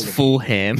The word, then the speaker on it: Fulham: For I'll pronounce the whole word, mate. It's Fulham.